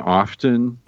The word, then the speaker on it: often: Dory sent in the JMO of pronouncing the T in often. – Oh, correct. – That's pretentious and incorrect.